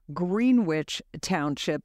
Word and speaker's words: Greenwich Township: By the way, Vince, I should mention you are the mayor of Greenwich Township. That's the pronunciation. I guess I had Connecticut on the brain when I did this interview.